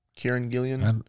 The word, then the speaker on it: Kieran Gillen: Kieran Gillen.